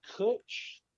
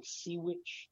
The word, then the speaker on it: Cwtch: It looks like Sea Witch but it's uh, spelled Cwtch, which is uh, Gaelic for hug.